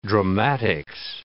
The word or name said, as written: dramatics